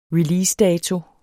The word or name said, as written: releasedato